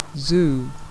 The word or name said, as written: zoo